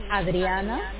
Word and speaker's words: Adriana: Yes, my name is Adriana. – Can you spell it for me? – A-D-R-I-A-N-A.